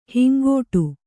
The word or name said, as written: ಹಿಂಗಟ್ಟು